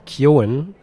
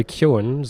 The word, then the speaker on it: Keoghan: Und ja, der Vater der Hauptfigur Bailey wird gespielt von Barry, und das habe ich heute gelernt, Keoghan, also so wird er ausgesprochen, Barry Keoghan, so spricht man es offenbar auf Irisch aus,